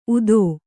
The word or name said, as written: ಊದ